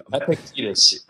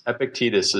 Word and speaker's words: Epictetus: Epic, Epictetus. I don't know how you pronounce that, but that's where the Epictetus. Epictetus is his name. – Epictetus.